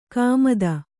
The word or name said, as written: ಗುಮ್ಮತಿ